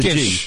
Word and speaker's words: Gish: I think so. G-I-S-H. It's like fish with a Gish.